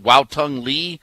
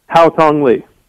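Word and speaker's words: Haotong Li: Is it Wow Tung Lee? Is that how you pronounce it? What is it? – Hao Tung Lee. – Okay, so Hao Tung Lee, you've got a guy seemingly out of nowhere that's leading a tournament like this.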